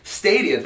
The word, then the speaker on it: Stadia: It's called Stadia. That's how you pronounce it. Thank you. S-T-A-D-I-A.